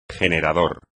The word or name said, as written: generador